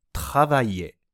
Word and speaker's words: travailler: 実はこの単語は、 travailler と発音します。